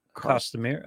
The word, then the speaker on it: costumier: At our red light seances, we have Sue Brown, who is pretty much a costumier. How do you say it? Somebody who makes costumes. – Costumier. Okay. – Costumier.